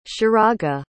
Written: sheraga

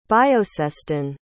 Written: bioseston

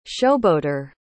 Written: showboater

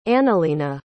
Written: analena

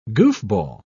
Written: goofball

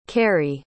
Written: kerry

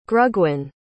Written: grugwyn

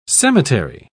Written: cemetery

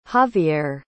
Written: xavierre

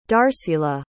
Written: darcila

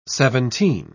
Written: seventeen

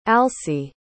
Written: alcee

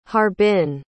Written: harbin

